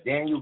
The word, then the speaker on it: Danyeil: My name is Daniel Green on Facebook.